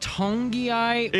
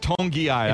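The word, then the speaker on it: Togiai: Is it Tongeai? – It's pronounced Tongeai. I know there's not an N in there, but the official phonetic spelling is Tongeai.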